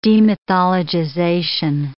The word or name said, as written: demythologization